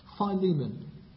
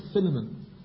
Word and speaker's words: Philemon: Go back to Philemon, or for those who prefer the Australian way of pronouncing it, Philemon.